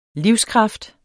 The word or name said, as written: livskraft